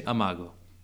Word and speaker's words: amago: How would you say amago? – Amago.